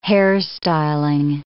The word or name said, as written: hairstyling